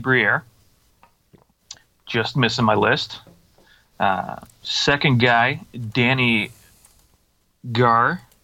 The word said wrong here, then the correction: Gare: not sure if that's really how you pronounce it correctly. Boy, yeah. – Gare, yeah, let's go with Gare.